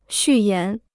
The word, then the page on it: 序言: xù yán